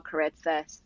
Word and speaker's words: Koritsas: The people can find me on Instagram and TikTok at Isabel Caritsas. It's spelled just like it sounds, KORITSAS.